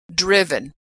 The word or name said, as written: driven